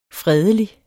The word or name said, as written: fredelig